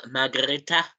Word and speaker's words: Margrete: Yes, good film, Margrethe. As it's pronounced, 7 out of 10 from me.